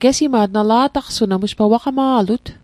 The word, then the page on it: gesima't: Mi'gmaq